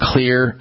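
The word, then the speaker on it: Xlear: We're looking at and talking about Clear nasal products, and we're honing in on this particular one for allergy season, Clear Max, M-A. X nasal spray. Clear is spelled with an X, X-L-E-A-R, at finer drug grocery stores everywhere.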